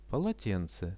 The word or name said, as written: polotence